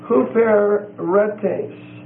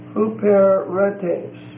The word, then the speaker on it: huperetes: Or might be huperetes. H-U-P-E-R-E-T-E-S. The first E and the last E are long E's, they're eights. So, long E for the first E, long E for the last one. H-U-P-E-R-E-T-E-S.